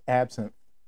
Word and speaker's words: absinthe: Say it correctly. – It's absinthe. – Absinthe. Okay, when you say it, I can repeat it.